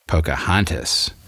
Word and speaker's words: Pocahontas: So here are the ways in which modern Virginians pronounce the names of the main protagonists in the book: Powhatan, Pocahontas, Opechancanough."